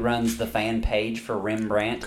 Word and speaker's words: Rembrandt: Wait, Rembrandt. I've been saying it right then. – No, you've been saying Rembart.